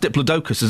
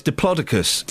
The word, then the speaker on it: Diplodocus: Turns out it's not pronounced Diplodocus, as I was told in the 70s. Oh, that's what I thought it was. – Yeah, I know, it's Diplodocus.